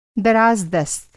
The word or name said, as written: दराज़-दस्त